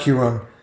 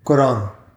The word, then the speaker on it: Quran: Not Quran, Quran.